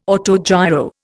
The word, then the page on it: autogiro: Indian